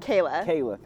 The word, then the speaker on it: Kayla: Kayla, thank you.